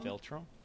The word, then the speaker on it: philtrum: Philtrum. – Philtrum? – Philtrum. – P-H-I-L-T-R-U-M.